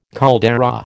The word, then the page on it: caldera: American English